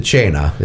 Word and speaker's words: cena: I would pronounce it Chena. It's an Italian word, Chena, which means dinner.